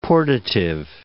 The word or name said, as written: portative